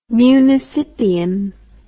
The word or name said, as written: municipium